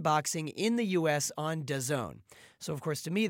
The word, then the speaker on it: DAZN: It's actually written as D-A-Z-N. I think it's a kind of funky name, but it's pronounced DAZN.